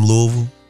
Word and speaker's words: Louisville: Are you calling from Louisville? – Absolutely.